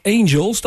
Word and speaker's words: Angel: Een angel van ook een wesp heeft een angel. Dat heb jij kennis meegemaakt. Maar het angel in dit geval wordt op zijn Engels uitgesproken als Angel.